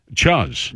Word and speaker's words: chuz: Why did you say it like that? – C-H-U-Z. – Chuzz. – There you go.